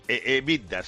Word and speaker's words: EBITDA: que es el acrónimo EBITDA. – EBITDA, sí.